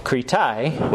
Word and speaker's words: Kritai: And then the Greek term is Kritai, which is also judges.